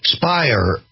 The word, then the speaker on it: Speier: Spire rhymes with liar.